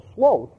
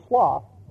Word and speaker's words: sloth: But I think I call it sloth with a long O, but it's spelled the same. It's sloth.